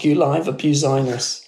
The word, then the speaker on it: Guliver Buzinus: It was like Gulliver Business, but pronounced Guliver Buzinus.